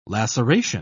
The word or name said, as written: laceration